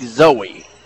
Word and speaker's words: Xoey: spells it X-O-E-Y, pronounced like Zoe.